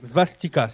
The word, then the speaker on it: svastika: La svastika, je crois que c'est comme ça qu'on prononce. – Euh, la svastika. – la svastika, c'est ça exactement.